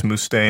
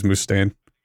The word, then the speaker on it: Mustaine: I think it's you got it right the first time. I think it's Mustaine. – I think it is Mustaine.